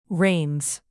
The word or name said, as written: reins